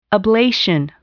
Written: ablation

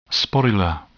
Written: sporular

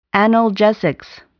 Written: analgesics

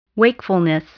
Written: wakefulness

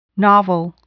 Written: novel

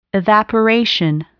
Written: evaporation

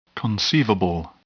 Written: conceivable

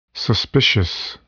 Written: suspicious

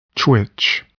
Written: twitch

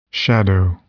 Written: shadow

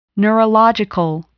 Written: neurological

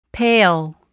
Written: pale

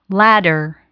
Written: ladder

